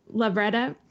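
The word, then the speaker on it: Lovretta: Michelle Lavretta, I believe is how you say her name. – Lavretta, to have Kenzie say that she's straight.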